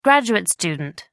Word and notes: graduate student: American English

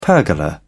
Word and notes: pergola: British English

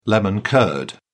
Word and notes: lemon curd: British English

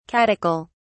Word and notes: catechol: British English

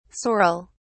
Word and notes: saurel: British English

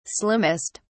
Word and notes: slimmest: British English

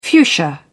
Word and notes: fuchsia: British English

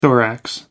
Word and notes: thorax: American English

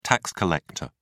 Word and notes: tax collector: British English

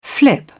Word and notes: flip: British English

